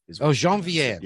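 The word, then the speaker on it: Geneviève: And she pronounced it Genevieve. – Oh, Genevieve. Yeah. Sorry. – Genevieve Bujold. Yeah.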